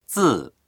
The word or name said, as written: zi4